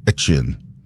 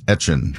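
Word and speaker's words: Etienne: And so he said, because he's back in Louisiana and now playing for the Saints, that he would go by the pronunciation that they would say in Louisiana, which always was like Etchin. – So it's Travis Etchin.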